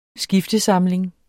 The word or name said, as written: skiftesamling